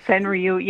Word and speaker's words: Senryu: How do you say Senryu? – Senryu? Is that how you say it? – Yeah, that's how I say it. Senryu.